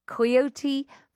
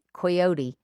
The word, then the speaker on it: coyote: And switching gears completely, Coyote versus Acme. Do you say coyote? – We say coyote. – Coyote, even though it's spelt with an O.